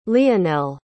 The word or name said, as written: leonel